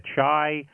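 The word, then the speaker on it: Qi: The one that I'm familiar with is, I think it's QUI, they pronounce it Chai.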